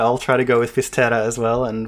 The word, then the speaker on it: Fisterra: to the village known in Spanish as Finisterre and in Gallego as Fisterra. So I think you're going to go with the Gallego pronunciation? – Yeah, I'm in full-on Gallego mode right now.